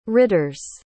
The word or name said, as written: ridders